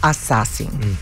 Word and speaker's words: Assassin: El reto de las pistolas de agua se llama Senior Assigning. ¿Cómo se pronuncia? Como de asesinar. Senior... – ¿Assassination? – No. – ¿Assassin? – Assassin. Senior Assassin.